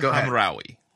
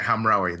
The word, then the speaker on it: Hamraoui: I cannot say her name. We were practicing. – As we have established on this show, you do have certain pronunciation issues. – Yeah. – So this one I can. Kira Hamraoui. – Yeah, Hamraoui. That's where we go.